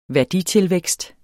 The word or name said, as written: værditilvækst